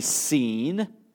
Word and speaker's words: Sin: The better translation or the better pronunciation of that word would be seen, and it has nothing to do with sin proper.